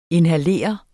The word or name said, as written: inhalere